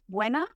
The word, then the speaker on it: Buena: Well, it's it's Buena, but it's pretty good what you said. And it's like there's a chocolate bar called Bueno, which you might know, and Buena's the feminine of that.